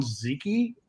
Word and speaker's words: Zyci: So I settled on Zyci, Z-Y-C-I.